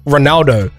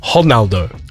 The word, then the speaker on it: Ronaldo: So it's not Ronaldo. Apparently it's Ronaldo. – Ronaldo. – But apparently in Portuguese, you pronounce your R's like that.